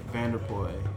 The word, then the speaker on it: VanderPuije: So my name is Mike Vanderpoy. – Vanderpoy. And the way that you spell it is? – So the last name is spelled VanderPuije. J is silent.